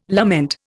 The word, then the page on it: lament: Indian